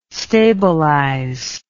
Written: stabilize

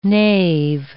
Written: nave

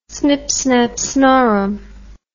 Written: snipsnapsnorum

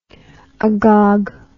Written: agogue

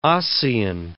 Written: ossein

